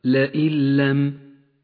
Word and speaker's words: لئن لم: أما النون فلا تلفظ ولذلك لونت بالرمادي، وأما اللام التي بعدها فتشدد، تقرأ هكذا لئن لم.